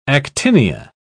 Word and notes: actinia: British English

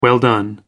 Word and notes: well done: American English

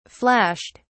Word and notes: flashed: British English

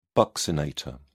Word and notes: buccinator: British English

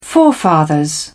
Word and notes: forefathers: British English

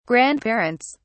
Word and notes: grandparents: British English

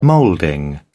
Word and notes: moulding: British English

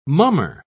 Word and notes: mummer: British English

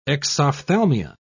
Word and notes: exophthalmia: British English

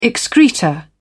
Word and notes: excreta: British English

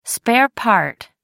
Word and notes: spare part: British English

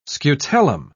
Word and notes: scutellum: British English

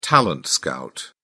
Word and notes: talent scout: British English

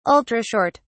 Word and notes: ultra short: British English

